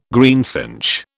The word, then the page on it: greenfinch: British English